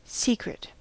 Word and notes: secret: American English

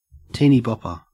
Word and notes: teenybopper: Australian English

